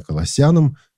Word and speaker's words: Колоссянам: Кстати, я буду произносить Колоссянам, потому что это люди, которые жили в греческом городе Колоссы. А есть еще и произношение Колоссянам. Ну вот, мы все-таки выберем произношение Колоссянам с ударением на втором слоге.